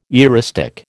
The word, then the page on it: eristic: American English